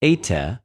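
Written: εἰτε

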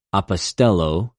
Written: ἀποστελλω

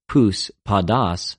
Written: πους,ποδος

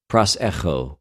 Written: προσεχω

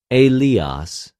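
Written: Ἠλίας

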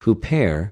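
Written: ὑπέρ

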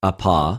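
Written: ἀπο

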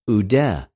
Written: οὐδε

